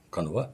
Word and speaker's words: канва: Ну, вот есть некая, так сказать, историческая канва, как ее правильно называют, канва. – Ну, канва, ударение, канва, да, да.